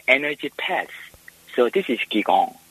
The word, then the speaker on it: Qigong: Oh, like Qigong is energy movement. – That's how you say it. So I said it wrong as well. – Yeah, like Qigong, Qigong, Qigong is same. – Yeah. – Depends on the country.